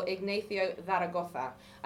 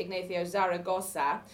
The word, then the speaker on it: Ignacio Zaragoza: under the leadership of General Ignacio Zaragoza. And that's how you pronounce it in Spanish, or Spain Spanish. Mexican Spanish would be Ignacio Zaragoza, as my partner, who speaks no Spanish, pronounced it last night, and I was like, small eye twitch.